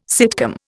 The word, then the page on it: sitcom: Indian